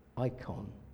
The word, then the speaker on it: eikon: And the word they chose was a Greek word, icon. That's spelled E-I-K-O-N, icon.